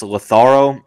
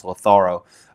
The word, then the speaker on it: Lazaro: Anna DeBeer for Raquel, I think it's Lotharo is how it's pronounced. It's spelled Lazaro, but I think it's pronounced Lotharo.